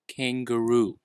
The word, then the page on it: kangaroo: American English